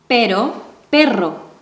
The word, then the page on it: pero: Spanish